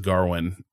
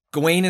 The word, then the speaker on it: Gawain: And when I need to commit to, I'm going to pronounce that, like, Arthur says Garwin. – So here's what I'll say. So Gawain is like, when I was looking up the pronunciation, that is sort of the accepted pronunciation.